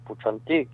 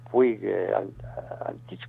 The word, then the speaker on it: Puig-Antich: con Puig-Antich, o Puig-Antich, como se quiera pronunciar, yo prefiero la pronunciación catalana.